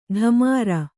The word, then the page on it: ಢಮಾರ: ḍhamār